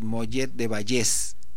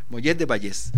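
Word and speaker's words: Mollet del Vallès: Me dice por ahí Pedro Bravo González que es acento en la E. Entonces es Mollet de Vallés, cerca de Barcelona. Mollet de Vallés.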